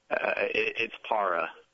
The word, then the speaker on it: Garciaparra: Para or para? – Para. – Well, I guess we could ask Nomar. The correct pronunciation, Nomar Garciaparra, is how I want to pronounce it. Who's closer to right here, Nomar? – It's para. Yeah, it's para. It's not para, it's para. So, yeah, you got it.